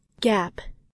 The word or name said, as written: gap